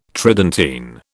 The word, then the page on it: Tridentine: British English